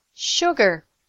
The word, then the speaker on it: sugar: Sugar. The S sounds like SH, sh.